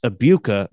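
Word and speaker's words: Egbuka: I mean, he's a stud, and it's it's pronounced Abuka. The G is silent.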